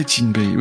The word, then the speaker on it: Tinbei: Déjà, on m'a rappelé que dans nos geôles croupissait un certain ex-contrôleur des décès, en la personne de Tinbei, c'est comme ça que ça se prononce? – Ouais, Tinbei, oui, oui.